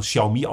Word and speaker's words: Xiaomi: schreibt sie mit X, Xiaomi geschrieben, also Xiaomi ausgesprochen, ist eigentlich ein Smartphone-Hersteller, der allerdings schon jetzt in nächster Nähe bereits ein fertiges E-Auto-Modell auf den Markt bringt.